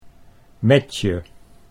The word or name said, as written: Metje